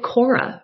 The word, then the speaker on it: Khora: And it lives at Cora. That's spelled Khora. If you want to give it a Google and support the incredible writers and editors that are there, I love them so much.